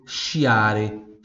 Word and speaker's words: sciare: Então, quando tem o SCI, fica com som de chi ou CHI, né? Chi. Tem o som de chi, a pronúncia de chi. Então, por exemplo, chiare, chiare, que em italiano se escreve S-C-I-A-R-E, chiare, chiare, que significa esquiar.